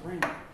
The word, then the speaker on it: rhema: The second one is rhema, pronounced rhema.